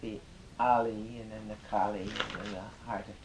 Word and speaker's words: Kali: Round its center of the lotus, round its center, white ali, A-L-I, long A. Clockwise. Then red Kali, long A, K-A-L-I, counterclockwise, then the heart of dependent arising, blue and I recite them in snake-like succession.